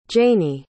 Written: jayni

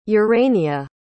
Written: urania

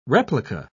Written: replica

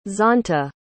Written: zonta

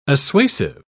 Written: assuasive

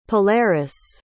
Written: polaris

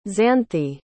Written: xanthe

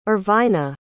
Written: erwina

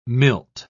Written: milt

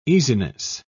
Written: easiness

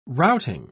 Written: routing

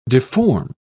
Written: deform

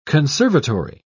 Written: conservatory

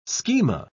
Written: schema

